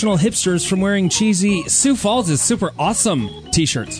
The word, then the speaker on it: Sioux: The city was able to halt a movement to start pronouncing the X in Sioux.